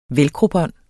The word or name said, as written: velcrobånd